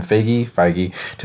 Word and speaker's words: Feige: And like I remember reading some kind of reading an interview with Kevin Feige.